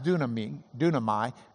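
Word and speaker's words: dunamai (δύναμαι): The U in Greek, if it's between two consonants, you write it in English as Y. But it's still pronounced dunamai.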